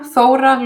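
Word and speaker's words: Þóra: So it's pronounced Kolbrun Thora Löve.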